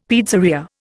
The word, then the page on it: pizzeria: Indian